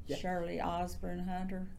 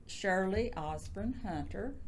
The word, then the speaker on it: Shirley Osborn Hunter: You want Shirley Osborne Hunter? – Yep. – Okay. Shirley Osborne Hunter. – Okay, and spell it. – S-H-I-R-L-E-Y-O-S-B-O-R-N-H-U-N-T-E-R.